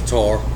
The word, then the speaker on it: Qatar: Qatar.